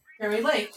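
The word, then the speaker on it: Kari Lake: It's spelled K-A-R-I, then Lake. – There you go. – Carrie Lake. – You know, I like that other guy that called in. I wasn't sure how to pronounce that. – Carrie Lake, she's from Arizona, right?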